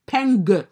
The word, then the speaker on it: Peng: Peng with a hard G. At the end, do you hear me? The real G's can hear the hard G at the end of Peng.